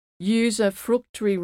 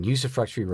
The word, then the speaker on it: usufructuary: Now, you've worked on something, excuse my pronunciation, I always stumble over this word, usufructuary rights. Is that how you say it? – Yeah. My specialty is in usufructuary rights, which are hunting, fishing, and gathering rights that attach to the land.